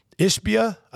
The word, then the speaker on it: Ishbia: Mac Ishbia, I believe that's the way you pronounce that. – Correct.